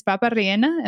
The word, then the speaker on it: papa rellena: ¿Es papa rellena? Am I saying it right? – Yeah.